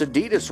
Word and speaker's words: Adidas: When you lose weight, you breathe out the fat as carbon dioxide, and Americans pronounce Adidas wrong. We're supposed to put a slight emphasis on the first syllable, not the second.